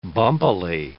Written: bumpily

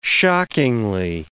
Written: shockingly

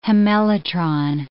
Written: hemelytron

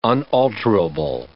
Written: unalterable

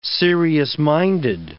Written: serious-minded

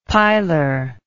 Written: pilar

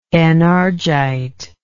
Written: enargite

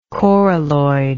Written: coralloid

